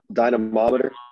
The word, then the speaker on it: dynamometer: It's a dynameter. Is that how you say it? – Dynamometer.